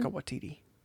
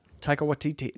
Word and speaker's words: Taika Waititi: No, that's how he says it. I'm trying to say it like he says it, because I say Taika Waititi. – No. – And he says Taika Waititi. That's how he says it. Because I'm trying to, he knows how to say his name.